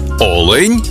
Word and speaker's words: олень: І в однині, і в множині наголос падає на перший склад. Олень та олені.